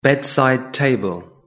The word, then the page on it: bedside table: British English